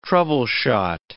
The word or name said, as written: troubleshot